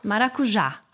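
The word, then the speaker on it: maracujá: Ah, wie spricht man das richtig aus? Maracuja. Ah, Maracuja. Ja, Maracuja. Maracuja. Ach, gar nicht die Betonung auf Maracuja, sondern Maracuja. Genau, Maracuja.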